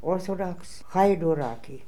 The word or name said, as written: Orthodoxhydooraki